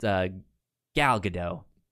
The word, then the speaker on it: Gal Gadot: Gail Godot, or however you say her name. – Well, not Gail. – It's Gal Gadot. – Yeah, I think it's Gal Gadot. – Gal Gadot. I'm. – I think everybody thinks place. – It's supposed to be a silent T, but it is in fact a loud T. – It's a loud T. It's a hard T.